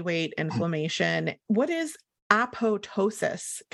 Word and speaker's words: apoptosis: Apop- people say apoptosis.